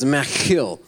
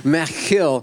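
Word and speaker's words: Maschil: The description of the psalm is that it is the way you pronounce it is machil. When you're a little bit congested, that's really difficult. But typically, it's a machil, which means a didactic poem.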